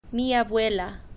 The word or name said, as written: mi abuela